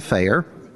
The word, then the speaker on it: Feyer: I am Rabbi Zev Chaim Feyer.